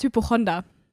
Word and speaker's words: Hypochonder: Hypo ich habe dich letztens im Podcast schon irgendwann mal Hypochondra genannt und danach habe ich gegoogelt ähm, und es heißt Hypochonder. Hypochonder. Ich hab's falsch gesagt.